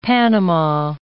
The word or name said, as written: Panama